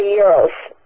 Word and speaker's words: gyro: It was a gyros. – Uh-huh. – Am I pronouncing it right? – I think, yeah, gyro. It's G-Y-R-O. Looks like gyro. Glad that it's pronounced gyro.